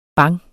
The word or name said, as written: bang